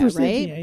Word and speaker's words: forsythia: Although I was told this weekend that it is forsythia, that is the proper pronunciation that is named after a gentleman from the early times of horticultural societies. – Named Forsythe? – Named Forsythe. And so it should be Forsythia.